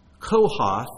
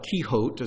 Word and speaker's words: Kohath: Kohath, or Kehote, is another way of pronouncing it.